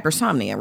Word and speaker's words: hypersomnia: hypersomnia, right? – That's it.